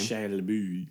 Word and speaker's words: Shia LaBeouf: Shia LaBeouf.